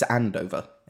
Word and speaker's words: Andover: It's Andover.